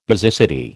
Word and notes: basicity: American English

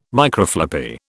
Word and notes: Microfloppy: British English

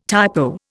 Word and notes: typo: Indian

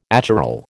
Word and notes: achiral: American English

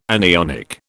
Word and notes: anionic: British English

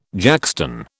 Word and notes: jackstone: British English

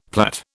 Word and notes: plait: British English

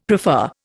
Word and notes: prefer: Indian

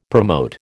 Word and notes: promote: American English

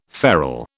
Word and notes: farl: British English

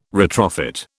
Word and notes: retrofit: British English

